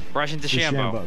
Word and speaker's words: Bryson DeChambeau: Bryson DeChambeau.